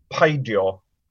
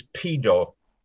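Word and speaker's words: peidio: You know, like there's another joke, the word for to, If you say don't do something, the word for don't is paidio, or you can pronounce it as pedo, you see?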